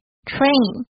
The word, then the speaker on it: train: 但是很多人还是偶尔会忘记它是长音，大家要注意一下 A 的发音 train。